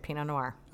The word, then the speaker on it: Pinot Noir: Pinot Noir.